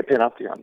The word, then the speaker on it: panopticon: And I love the word you use because I've never heard of this before, but the panopticon, is that how you say it? – Yeah, panopticon.